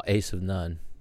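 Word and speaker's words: Asivnon: Oh, Ace of None. – I didn't know that. – Yeah, it's not very intuitive because it's a made up word. But that's how it's pronounced.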